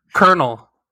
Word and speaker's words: colonel: Like if you had never heard Colonel, like like if you've never heard that pronounced ever, you would call that shit Colonel or something like that. – Big facts. – Like you have no indication that there's a motherfucking R in there.